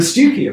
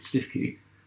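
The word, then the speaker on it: Stiffkey: I mean, Stewkie Blues are cockles, local seafood, but it's actually spelt Stifkie, and most people in Norfolk would pronounce it Stifkie. But if you were local, you would probably be allowed to call it Stewkie.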